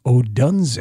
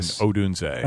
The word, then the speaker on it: Odunze: The intern already has Pat Hughes' voice down when he says, Odunze. – Yeah, it is very good pronunciation on the O in Odunze.